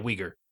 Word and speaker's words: Uyghur: Is that how that's pronounced? Yeah, Uyghur.